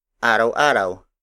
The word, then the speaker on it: araw-araw: For example, araw-araw. Araw-araw. Araw-araw. Araw-araw is spelled as A-R-A-W hyphen A-R-A-W.